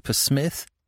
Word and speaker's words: Psmith: I don't know that I can say that, Mr. Psmith. – The P is silent, as in shrimp, he reminded her.